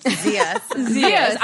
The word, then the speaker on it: Zias: Zias.